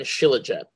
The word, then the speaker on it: Shilajit: There are two other plants we do want to talk about, which is kava and Shilajit.